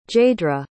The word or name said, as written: jaydra